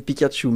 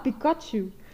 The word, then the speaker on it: Pikachu: Ja, dat is als we iets zeggen over Pokémon, het Pikachu. – Een Pikachu. – Ja, ook zo nog met de verkeerde klemtoon. Okay. Maar altijd, als het over Pokémon ging, dan was het, Pikachu.